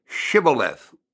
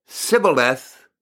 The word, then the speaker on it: Shibboleth: So they go, "If you say no," they say, "All right, say Shibboleth," S-H, Shibboleth. If he said Sibboleth, because he could not pronounce the word correctly, they seized him and killed him at the fords of Jordan.